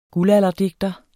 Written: guldalderdigter

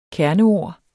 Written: kerneord

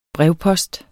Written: brevpost

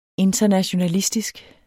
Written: internationalistisk

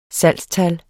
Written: salgstal

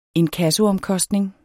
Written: inkassoomkostning

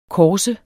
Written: kors

